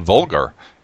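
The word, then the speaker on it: Volgarr: So this week I was going to talk about a game called Vulgar, and that's V-O-L-G-A-R-R, not the, you know, surely pronounced like the word that is synonymous vulgar? – With, yeah, offensiveness, vulgar.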